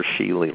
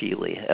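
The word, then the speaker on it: Scheele: Yeah, it's S-C-H-E-E-L-E, S as in Sheely, S as in Sam, right?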